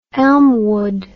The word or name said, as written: Elmwood